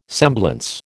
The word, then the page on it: semblance: American English